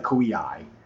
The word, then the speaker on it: Koei: Because I always say Koei. – I believe I took Japanese in high school. I believe the correct way to say it, like phonetically, is like Koei.